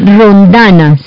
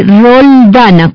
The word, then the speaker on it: roldana: No se dice rondana, se dice roldana con L, rol, del rol, roldana.